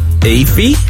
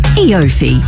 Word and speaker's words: EOFY: Once a year, there's a word you can read but can't quite pronounce. – Eafy? – E-offy? – Eafo? – It's Eofy.